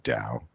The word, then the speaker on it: Daou: And that's D-A-O-U, but it's pronounced Dow.